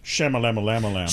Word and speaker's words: Shyamalan: Wait, I think you pronounced it wrong. It's Shyamalan. – Shyamalan.